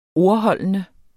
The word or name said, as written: ordholdende